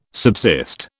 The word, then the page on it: subsist: British English